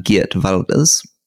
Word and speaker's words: Geert Wilders: The Netherlands, where the second largest party is this thing called the Party of Freedom, with only one member, who's this man, Geert Wilders.